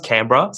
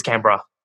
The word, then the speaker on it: Canberra: So it's pronounced Canberra. So if you're from Canberra, you pronounce it Canberra.